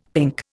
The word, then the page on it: pink: Indian